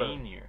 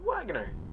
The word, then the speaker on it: Wagner: There is a lot Wainier. – Of... – Wainier. – What? – Wainier. – It's Wagner. – Wainier. – Why would you pronounce it Wainier? There's no Y.